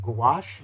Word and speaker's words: gouache: Is it gouache? – Gouache, yeah. – Gouache. You got it.